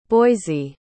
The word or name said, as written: Boysie